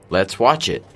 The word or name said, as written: let's watch it